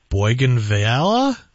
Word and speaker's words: bougainvillea: I'm wondering how to overwinter my bougainvillea. – Oh, that's pretty darn close. Yeah, it was close.